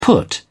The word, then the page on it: put up: British English